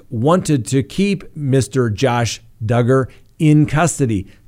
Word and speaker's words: Duggar: Now, Remember Josh Duggar? That's right. Rhymes with hugger.